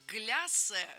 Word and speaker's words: гляссе: Очень многие слова иностранные, заимствованные, но как с этим кофе гляссе жить теперь, я не знаю. Я, кстати, до сегодняшнего дня не знала, что кофе не глиссе, а гляссе.